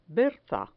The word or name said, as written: berza